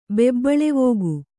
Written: ಬೆಬ್ಬಳವೋಗು

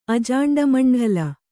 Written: ಅಜಾಂಡಮಂಢಲ

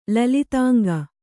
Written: ಲಲಿತಾಂಗ

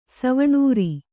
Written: ಸವಣೂರಿ

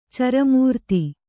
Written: ಚರಮೂರ್ತಿ

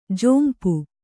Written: ಝೊಂಪು

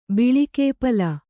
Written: ಬಿಳಿಕೇಪಲ

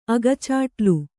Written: ಅಗಚಾಟ್ಲು

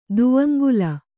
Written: ದುವಂಗುಲ

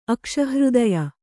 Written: ಅಕ್ಷಹೃದಯ

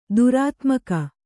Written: ದುರಾತ್ಮಿಕೆ

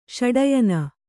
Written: ಷಡಯನ

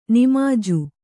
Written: ನಿಮಾಜು

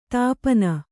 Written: ದೀಪನ

